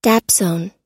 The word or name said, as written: dapsone